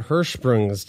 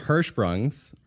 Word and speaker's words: Hirschsprung's: Hey, speaking of earwax and overproducing earwax, how about Hirschsprung's disease? – Exactly. – Is that a good pronunciation of that? – Yeah, I think I nailed it. Hirschsprung's, or if it's easier, aganglionic megacolon.